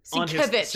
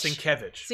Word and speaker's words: Sienkiewicz: Well, he spells it Sienkiewicz. Sienkiewicz.